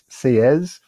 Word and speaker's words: Sieyès: And this is also the context for this very influential, very important, long document that you have um, by the Abbe Sieyès, S-I-E-Y-E-S is pronounced Sieyès.